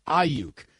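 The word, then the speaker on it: Aiyuk: The fact that there isn't a producer in Tony Romo's ear, like instantly saying, Ayuk, Tony, it's Ayuk, or somebody handing him an actual pronunciation guide.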